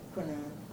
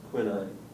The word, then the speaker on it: quinine: How do you say that? – Quinine. – Quinine. That's what I thought. You know, I can never remember to say it the way people here say it.